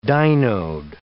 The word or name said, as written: dynode